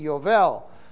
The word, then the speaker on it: Yovel: It was the B was actually pronounced as a V, Yovel.